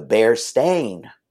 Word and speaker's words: Berenstain: Berenstain. – That's what most people would say. The Berenstain Bears, it's Stein. No. It was always Stain. The Berenstain Bears.